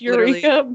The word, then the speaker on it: Yreka: I know it's not Yreka, pronounced like, Yreka, but I think it is Yreka.